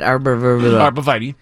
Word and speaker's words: arborvitae: I have a neighbor who has, I don't know how to say it, arborvitae.